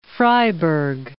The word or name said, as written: Freyberg